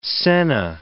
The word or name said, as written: senna